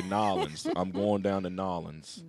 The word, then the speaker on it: New Orleans: If you say New Orleans, you got to say it right when you're down in Nolens. Nolens. That's how they say it down there.